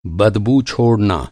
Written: बदबू

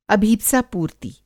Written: अभीत्सा-पूर्ति

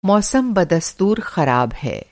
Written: बदस्तूर

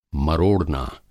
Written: मरोड़ना

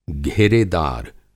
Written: घेरेदार

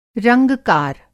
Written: रंगकार

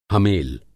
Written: हमेल

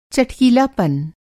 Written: चटकीलापन

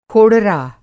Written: खोड़रा

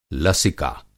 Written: लसिका

